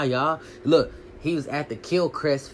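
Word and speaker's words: Quellcrist: It's a K, y'all. It's a Q, how you actually spell her name, but it's pronounced with, like, a K. It's weird. I don't know. But Kilcrest Falconer. To be honest, if you say the quill part, you really gonna fuck yourself up.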